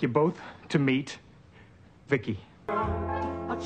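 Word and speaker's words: VICI: V-I-C-I, I, I pronounce it Vicky.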